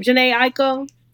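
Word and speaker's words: Jhené Aiko: How do you say her name? Janae Aiko? Mm-hmm. Yeah. – There you go.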